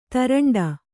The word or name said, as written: ತೇರಂಟಿ